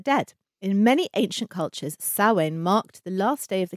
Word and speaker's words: Samhain: So, for all Wiccans, Samhain is held on October 31st. – Well done. I'm really pleased that you pronounced that. – Thank you. – Because it's actually, it's not spelled how it sounds.